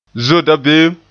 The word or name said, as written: zoe dabeem